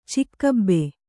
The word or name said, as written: ಚಿಕ್ಕಬ್ಬೆ